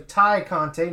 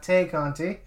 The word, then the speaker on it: Tay Conti: All right, next up, we had a trios match as Ruby Soho, Anna Jay, and I found out it's actually Ty Conti, not Tay Conti.